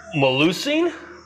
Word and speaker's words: Melusine: Melusine? See? How bad did I butcher it? – That's about right, actually. Every time I want to put the S earlier than it is.